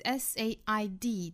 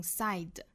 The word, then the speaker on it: said: 那后面这个单词 SAID 这个词呢，读成 said 元音 A 的音不要读成了 I，不要看到字母 AI 拼写就读成 side，也不要把它口型张得过大读成了 sad，应该是单元音 A， said 口型不要过大。